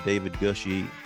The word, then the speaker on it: David Gushee: For those of you who are regular listeners, I just interviewed David Gushee. Did I say it right? – Yes.